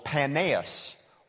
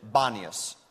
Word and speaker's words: Paneas: And it was called Panaeus or some pronounce it Panias. You would spell it P-A-N-E-A-S.